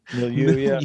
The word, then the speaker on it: milieu: How much do you think like this, whether we call it postmodernism, whatever this middle, how do I say it, milieu? milieu, yeah.